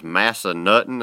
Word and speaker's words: Massanutten: I know I'm not pronouncing it right, but it was Massanutten.